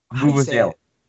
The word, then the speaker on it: vuvuzela: Yeah, they use the Vuvuzela. What is it? Is Vuvuzela? – Thank you.